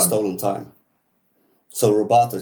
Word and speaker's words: rubato: Sorry, it's Rubato.